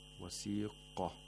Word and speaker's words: وَسِيقَ: Verse 73, وَسِيقَ الَّذِينَ اتَّقَوْا رَبَّهُمْ إِلَى الْجَنَّةِ زُمَرًا. وَسِيقَ, the qaf in وَسِيقَ has tafkhim. We have to pronounce it with a full mouth and with the mouth opening vertically. وَسِيقَ.